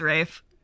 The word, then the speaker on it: Ralph: It's always been pronounced Rafe.